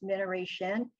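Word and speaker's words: Minnareshin: That's Mineration, Minna spelled with two N's, M-I-N-N-A-R-E-S-H-I-N.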